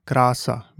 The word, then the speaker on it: krása: A is pronounced like the E in father. An example is krása, meaning beauty, or pták, meaning a bird.